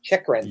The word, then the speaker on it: Chychrun: Chychrun? – Chychrun.